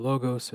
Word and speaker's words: Logos: So they started a school called the, depending on your pronunciation of Greek, either the Logos or the Logos school.